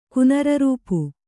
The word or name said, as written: ಕುನರರೂಪು